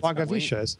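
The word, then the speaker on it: Langewiesche: Hey, I looked up the pronunciation, by the way. – Longavisha. – William Longavisha. You had it correct.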